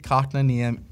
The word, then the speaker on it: Kotkaniemi: Jesperi Kotkaniemi. Kotkaniemi scores in his return to Montreal. – You're really pronouncing that first part. – That's how you say it.